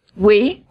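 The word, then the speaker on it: Oui: Oi, is that We? – We, okay. Sorry about the mispronunciation of the French, but We Magazine and other regional national publications.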